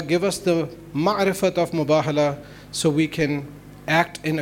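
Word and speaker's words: Mubahala (مباهلة): It's a ghalat al-am that sometimes is called Mubahila. It's on for the Hoza students, mashallah. You all know that it's on the wazn of Mufa'ala. So that would be Mubahala. The correct pronunciation would be Mubahala.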